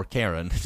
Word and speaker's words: Carron: the River Carron. Or Karen, depends how fancy you want to get with the pronunciation today.